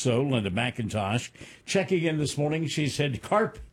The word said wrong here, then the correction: carpe: pumpkin. – I'm pretty sure it's carpe pumpkin. – Oh, well, excuse me. I'm sorry. You, the purveyor of pronunciations here in the radio station. Yes, it is carpe.